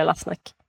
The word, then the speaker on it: Lassnack: Väldigt så här som det låter. Eh, och det är från Tyskland, eller så är det är tyskt. Så att, ja. – Man kanske uttalar det annorlunda i Tyskland, jag vet inte. Men vi säger Lassnack.